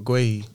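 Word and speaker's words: Guéhi: Mark Guehi. – Mark Guehi.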